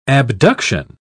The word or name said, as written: abduction